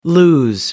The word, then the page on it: lose: American English